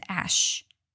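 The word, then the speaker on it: Æ: 马斯克的儿子的名字有来头，他的 X 就是代表未知变量，A 和 E 的连字在拉丁文发音是 ash，代表爱或人工智慧。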